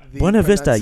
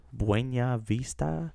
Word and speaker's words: Buena Vista: No, he pronounced it like there was an ñ in the N, and there's not. – Like Buena Vista.